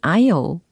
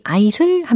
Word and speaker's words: aisle: 특히 이 walk down the aisle, aisle이라는 발음으로 들렸는데 철자는 a-i-s-l-e이라는 철자입니다. 통로를 뜻하는 어, 단어가 되는 거죠. a i s l 이라고 해야지 아이슬 하면 안 되고요. 아이슬레도 아닙니다. aisle, a i s l i s l 이렇게 연습을 해보시고요.